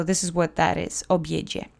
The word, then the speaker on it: obiedzie: The accent is on the middle syllable. There aren't any special phonemes. Pay attention to the DZI letter combination. That's the J sound. Obiedzie. Obiedzie.